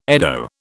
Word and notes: eddo: British English

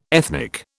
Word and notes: ethnic: British English

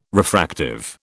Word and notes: refractive: British English

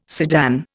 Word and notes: sedan: Indian

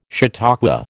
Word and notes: chautauqua: American English

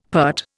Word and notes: peart: Indian

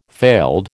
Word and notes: failed: American English

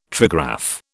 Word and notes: trigraph: British English

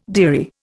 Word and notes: dearie: Indian

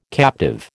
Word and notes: captive: American English

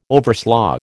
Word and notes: overslaugh: American English

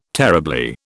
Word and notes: terribly: British English

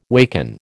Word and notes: waken: American English

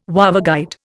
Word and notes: waveguide: Indian

